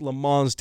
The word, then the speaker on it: Le Mans: And race cars and big racing teams, and think Le Mans, there you go, got to say it right.